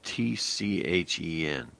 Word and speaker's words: Tchen: And by the way, it's spelled T-C-H-E-N. You know, a lot of times you hear chin, it's just, without the T in this case.